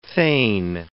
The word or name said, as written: thegn